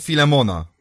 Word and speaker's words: Filemon: Zatem Filemon, ktoś powiedział tam w tyle, przy czym myślę, że to jest naprawdę poprawna wymowa tego.